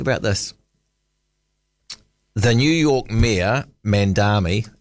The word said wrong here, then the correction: Mamdani: and now I haven't said his name out loud before, so it might have an extra syllable in there. I should get it right. Let me just Google that up. Mamdani, Zoran Mamdani. Flip, you'd love this, Bob.